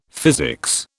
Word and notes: physics: British English

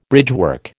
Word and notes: bridgework: American English

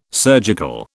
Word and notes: surgical: British English

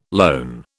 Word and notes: lone: British English

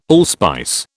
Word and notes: allspice: British English

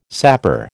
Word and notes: sapor: American English

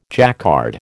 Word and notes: Jacquard: American English

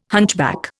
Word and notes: hunchback: Indian

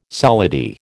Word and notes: solidi: American English

